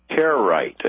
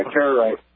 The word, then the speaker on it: Karaite: The word you're having a hard time pronouncing, they're called Karaite Jews. – Karaite. Is that how you say it? – Yeah, Karaite. – Karaite. Okay, I've read that over the years, and nobody's ever told me how to pronounce it, sir. It's Karaite. – Karaite, okay. – Yes, correct.